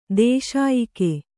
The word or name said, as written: ದೂಸೆಯಕ್ಕಿ